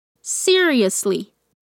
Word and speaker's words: seriously: Our first aid kit is Atenção para essa pronúncia, vamos dividir a palavra. Se, seriously, depleted.